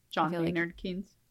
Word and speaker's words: John Maynard Keynes: Okay, an economist, John Maynard Keynes. – John Maynard Keynes.